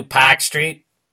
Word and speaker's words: Park Street: Yeah, they pronounce it Pack without Street. The accent. They don't do Pack Street. No, they don't do that. That now it would be, the next stop is Park Street.